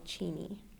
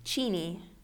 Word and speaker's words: Cheney: It's a small town right outside of Spokane called Cheney. – Called? – Cheney. – Cheney. – Spelled like Cheney. Which a lot of people pronounce it that way, but locals say Cheney.